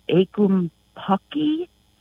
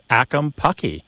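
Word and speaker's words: ackumpucky: I think you would pronounce it akumpaki.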